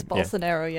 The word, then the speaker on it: Bolsonaro: I don't know if I'm saying this right, but it's Bolsonaro. I don't know if I'm absolutely right. I think it's Bolsonaro, yeah.